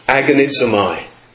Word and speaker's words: agonizomai: One word in the Greek language, that word is pronounced agonizomai. We get our English word agony from it.